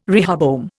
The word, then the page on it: rehoboam: Indian